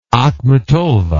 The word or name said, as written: Akhmatova